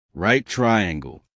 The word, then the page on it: right triangle: American English